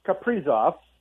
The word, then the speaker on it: Kaprizov: Well, I saw a tweet, by the way, Kaprizov, and I'm going to take forever to pronounce that the right way. But I think you said it right.